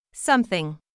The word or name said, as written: something